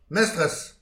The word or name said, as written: mestrez